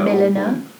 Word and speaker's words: milliner: It's like a milliner. – Oh, I knew that. – A milliner? – Yeah. – Is that how you say it? I don't know. – Yeah, milliner. – Didn't know that.